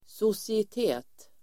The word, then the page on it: societet: [sosiet'e:t]